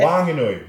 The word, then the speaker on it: Whanganui: Wanganui. Wanganui. Come on, guy. Wake up. I've come to Wanganui and I've learned how to say it a bit better.